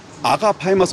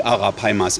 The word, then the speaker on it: Arapaima: Es ist der Arapaimas oder sind die Arapaimas? Ist das zu Plural und Singular zugleich? – Es ist beides dasselbe. Die Aussprache ist immer mal ein bisschen unterschiedlich. Der eine sagt Arapaima, der andere sagt Arapaima.